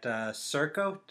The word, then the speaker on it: Serco: Now, while pursuing your degree, you've been working at Serco. Did I pronounce that right? – Yeah.